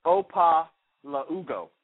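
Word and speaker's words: Okpalaugo: The K is silent. It's Opa-la-ugo.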